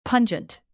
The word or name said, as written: pungent